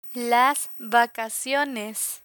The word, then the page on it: vacaciones: Spanish